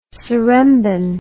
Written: seremban

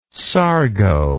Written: sargo

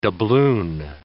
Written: doubloon

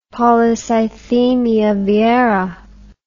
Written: polycythemia vera

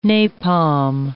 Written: napalm

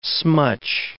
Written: smutch

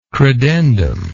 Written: credendum

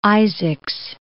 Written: Isaacs

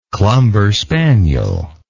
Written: clumber spaniel

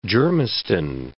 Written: Germiston

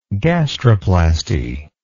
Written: gastroplasty

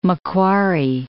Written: Macquarie

